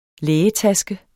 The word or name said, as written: lægetaske